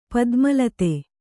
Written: ಪದ್ಮಲತೆ

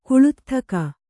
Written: ಕುಳುತ್ಥಕ

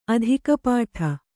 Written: ಅಧಿಕಪಾಠ

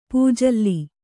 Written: ಪೂಜಲ್ಲಿ

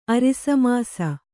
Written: ಅರಿಸಮಾಸ

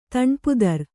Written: ದಾನಪತ್ರ

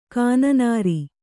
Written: ಗೊನ್ನೂರು

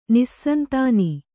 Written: ನಿಸ್ಸಂತಾನ

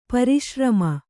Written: ಪರಿಶ್ರಮ

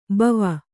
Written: ಭವ

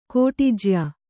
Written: ಕೋಟಿಜ್ಯಾ

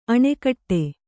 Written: ಅಣೆಕಟ್ಟೆ

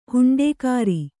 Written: ಹುಂಡೇಕಾರ

